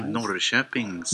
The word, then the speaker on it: Norrköping: I'm in Norrköping. How's that for pronunciation, guys? – Norrköping, Sweden.